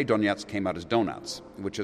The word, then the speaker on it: Donetsk: It's Donetsk.